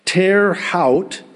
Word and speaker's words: Terre Haute: Terre Haute, T-E-R-R-E-H-A-U-T-E.